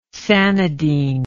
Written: sanidine

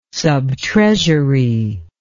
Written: subtreasury

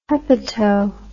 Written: hepato

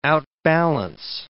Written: outbalance

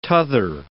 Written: tother

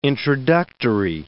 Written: introductory

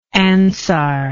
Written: ansarian

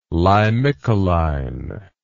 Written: limicoline

